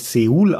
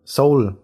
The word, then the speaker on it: Seoul: Da erst habe ich gelernt, nach 37 Lebensjahren, dass man die südkoreanische Hauptstadt ja gar nicht Seoul ausspricht, sondern Seoul, wie die Musik. Wer soll denn sowas wissen? Das macht überhaupt keinen Sinn. Geschrieben wird hier Seoul, deswegen sage ich auch weiterhin so dazu.